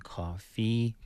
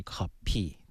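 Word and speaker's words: 커피: 我举几个例子，比如说 copy 是什么啊？coffee，对，好，那为什么是 p 呢？不是 coffee 呢？就因为韩语当中的没有 fu 啊这个音哈，所以不是 coffee，是 copy，对，是更加相近的发音。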